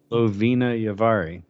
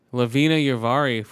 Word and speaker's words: Lovina Yavari: Lavara Yarvi. How do you pronounce her name? – Lovina Yavari. – Lovina Yavari, former guest of the show.